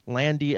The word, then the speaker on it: Landi: That's Eric, spelled with a K, Landy, L-A-N-D-I, on all of the social media garbage.